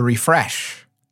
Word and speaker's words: Rephresh: There's a probiotic called Refresh. It's spelled R-E-P-H-R-E-S-H, which I enjoy very much for some reason. Refresh, that supposedly prevents yeast infections.